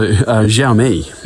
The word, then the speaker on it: Xiaomi: Xiaomi? Xiaomi? – Yeah, not bad. So Xiaomi.